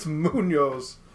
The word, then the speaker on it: Muniz: It's because you two don't know how to say his name correctly. – Like, it's Frankie Muniz. – It's Muniz.